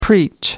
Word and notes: preach: American English

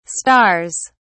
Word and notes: stars: British English

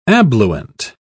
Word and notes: abluent: British English

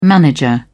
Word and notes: manager: British English

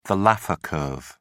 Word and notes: Laffer curve: British English

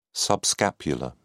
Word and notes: subscapular: British English

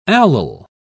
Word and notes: allyl: British English

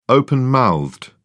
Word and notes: open-mouthed: British English

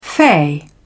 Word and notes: fey: British English

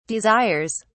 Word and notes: desires: British English

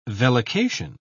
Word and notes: vellication: British English

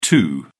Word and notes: too: British English

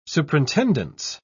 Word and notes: superintendence: British English

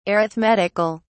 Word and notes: arithmetic(al): British English

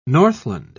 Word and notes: northland: British English

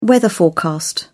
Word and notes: weather forecast: British English

